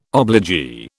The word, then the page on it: obligee: British English